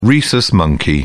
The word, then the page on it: rhesus monkey: American English